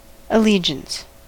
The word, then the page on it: allegiance: American English